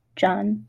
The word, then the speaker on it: Jan: His name was John, pronounced John, but spelled Jan.